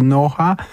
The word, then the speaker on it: NOHA: 18,5 minuty po godzinie drugiej, program trzeci Polskiego Radia, audycja nazywa się Gdzie Grabek Mówi Dobranoc, Gdzie Grabek mówi NOHA, tak można wymawiać nazwę tego projektu, można mówić po polsku, tak jak niektórzy NOHA, ale można też rozwinąć skrót Noise of Human Art.